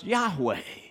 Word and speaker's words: Yahweh: It uses the Hebrew word that we think is pronounced Yahweh,